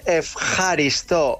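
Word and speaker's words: efjaristó (ευχαριστώ): Se dice efjaristó.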